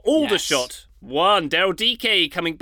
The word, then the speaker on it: Aldershot: Aldershot.